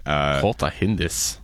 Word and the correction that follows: kalte Hände: Yeah, kalte Hände, wärmes Herz.